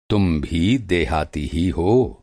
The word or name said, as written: देहाती